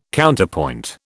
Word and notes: Counterpoint: British English

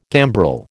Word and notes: gambrel: American English

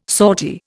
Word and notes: sortie: Indian

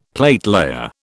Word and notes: platelayer: British English

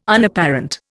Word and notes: Unapparent: Indian

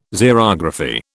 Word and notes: Xerography: British English

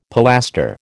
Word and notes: pilaster: American English

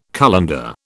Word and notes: colander: British English

